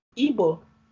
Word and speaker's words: Igbo: I know you posted about how the pronunciation and spelling of our culture and our people is not Igbo, I-B-O. Igbo is the way it's spelled and pronounced.